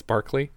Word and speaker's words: Barclay: It's spelled B-A-R-C-L-A-Y. It's Barclay.